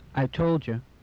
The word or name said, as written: I told you